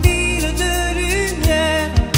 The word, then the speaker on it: Ville de Lumière: Ville de lumière, j'ai besoin de toi. – Bien pronunciado sería Ville de Lumière.